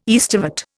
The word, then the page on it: aestivate: Indian